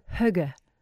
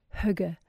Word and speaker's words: hygge: Another one that's very, very popular these days is the Danish term hygge, hygge, and it's spelled H-Y-G-G-E, hygge.